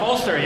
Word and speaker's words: Folster: All right, and Tom Folster, yes? – Yes.